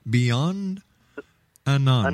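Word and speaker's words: Beyondananda: Beyond Ananda. – Ananda. Ananda. A lot all the Swamis have Ananda after their name, and I found out that Ananda means bliss.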